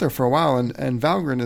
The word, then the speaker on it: Valgrind: And I look at it, it's spelled Valgrind. – Mm-hmm. – And you say it grinned as in smiling. And so I have been mispronouncing that my entire Valgrind using career.